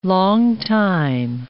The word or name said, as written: longtime